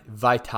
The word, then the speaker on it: Vaitai: So I'm going to say it one more time Halapu Vatai, Vaitai, I believe is how it's pronounced.